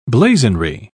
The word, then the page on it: blazonry: British English